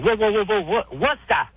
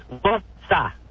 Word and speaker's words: Worcester: Worcester. Two syllables. Worcester.